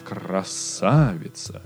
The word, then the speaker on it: красавица: То есть он говорит не красавица, а красавица, да? И вы все равно понимаете, о чем речь. Это может быть не так прикольно, и хочется, конечно, говорить «красавица», да, с правильной интонацией, с правильным ударением и так далее, но тем не менее, а, я не так много знаю иностранцев, которые могут это сделать с русским языком.